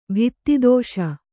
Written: ಭಿತ್ತಿದೋಷ

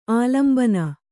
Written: ಆಲಂಬನ

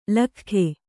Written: ಲೊಕ್ಕಿಗ

